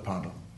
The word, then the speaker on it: Parnell: I pronounce his name Parnell, by the way. He did, so it's reasonable for me to do it. I was directed to do so when I was a first arts student here a very long time ago. Most people, of course, pronounce him Parnell, which is fine. But you will allow me that little eccentricity which he shared.